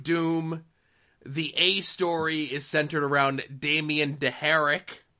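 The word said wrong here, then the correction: Darhk: Is that how you pronounce that? – It's Dark.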